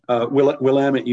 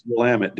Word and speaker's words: Willamette: Did I pronounce that correctly? Willamette? – Willamette University. – Willamette. Ooh, I was not going that direction. – Willamette. Oh, they have a saying. Yeah. It's Willamette, damn it.